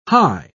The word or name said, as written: Hy